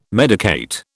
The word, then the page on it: medicate: British English